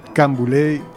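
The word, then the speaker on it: Canboulay: Camboulés, c'est la prononciation anglophone actuelle de canne brûlée.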